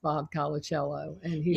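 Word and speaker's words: Colacello: So in the beginning, I wanted to read to them just a few sentences written by Bob, and I didn't even know how to pronounce his name, Colicello. – Bob Colicello. – I said it right.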